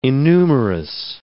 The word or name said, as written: innumerous